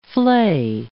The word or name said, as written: fley